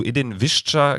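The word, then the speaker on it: Edin Višća: Zu Edin Visca ganz kurz.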